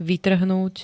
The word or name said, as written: vytrhnúť